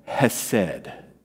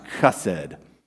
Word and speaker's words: hesed: And this word here that we find in Ruth, this word for kindness in verse 20, is a Hebrew word called hesed. Sometimes it's pronounced chesed, right?